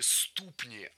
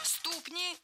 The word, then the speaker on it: ступни: И самые большие ступни относительно тела. То есть вот такие непропорционально большие ступни именно. – Ступни – неверно. Правильный вариант – ступни. Слово «ступня» имеет ударение на окончании во всех формах.